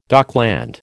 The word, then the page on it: dockland: American English